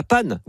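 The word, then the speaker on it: paonne: Vous connaissez le cri de la panne ? Oui, ça s'écrit paon, mais en fait, ça se dit panne, la femelle.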